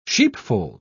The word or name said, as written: sheepfold